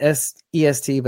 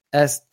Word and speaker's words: est: Dave, it's not EST, but like East, but S, don't pronounce the T.